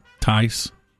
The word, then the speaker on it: Thies: I think it's pronounced Tice. T-H-I-E-S-S. Thies, Tice.